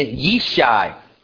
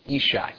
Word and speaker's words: Yishai: And Yishai, that is how you say Jesse in Hebrew. Yishai.